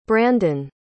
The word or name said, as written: brandin